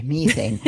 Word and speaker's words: methane: Methane is, I pronounce it the British way, methane.